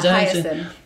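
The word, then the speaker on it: Hiaasen: How do you say that guy? Carl Hyacin? – Yeah, Hyacin.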